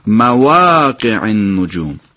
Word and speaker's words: مواقع: مواقع در نحوه تلفظ دوستان دقت بکنید، چون در خیلی از قرآن ها و مصاحف اصطلاح الف نداره، اون الف کوچیکی گذاشته شده، اونا بعد حتما شون دوستان ببینن مواقع النجوم.